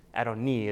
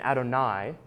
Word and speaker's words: Adoni: So Yahweh says to my Adonai, and actually in this case, it's Adoni is the correct pronunciation. And so here's the second part of the debate. It's Yahweh speaking, but who he is speaking to is merely a human servant, an Adoni, not an Adonai, which is what is referred to in other places to talk about God.